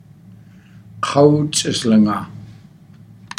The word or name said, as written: kaw ts'aslangaa